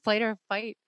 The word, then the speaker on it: flight or fight: flight or spite. I always say that wrong. Flight or fight response.